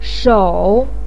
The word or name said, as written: shou3